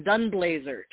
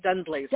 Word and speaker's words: Dunblazier: Uh, uh, option B. You had it, the second one, Dunblazier. Tracee Dunblazier. Dunblazier.